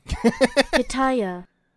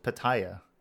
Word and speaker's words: pitaya: Pitaya. Pitaya. Pitaya. – Okay, so it's how it's spelled. Pitaya.